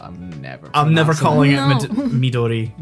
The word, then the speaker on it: Midori: And I hate to be the one to point it out, Maduri is not the right pronunciation. – Damn it! – Because it's a Japanese drink, so it's Midori, which is green, because it's, I think, watermelon flavoured.